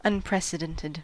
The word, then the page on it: unprecedented: American English